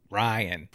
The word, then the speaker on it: Rian: No, it's not how you pronounce it. – Ryan. – Ryan. R-I-A-N. Ryan.